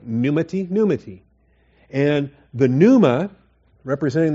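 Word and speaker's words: pneumati (πνεύματι): That is, the word is pneuma, but in the dative it's pneumati. And or pneumati, all right? We stress the first syllable. Pneumati.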